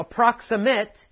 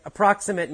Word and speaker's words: approximate: But if you use it as a verb, you say approximate, to approximate. Right? That you hear the A, it becomes a long A sound. To approximate. So approximate, the stress is different. Adjective, approximate. Verb, approximate.